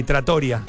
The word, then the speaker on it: trattoria: Pero me confundí. Trattoria. – Ah, trattoria. – Trattoria. – Ahí sí es con el acento en la I. – En los lugares donde se comen pastas. – Sin correcto. – Tilde, pero con acento.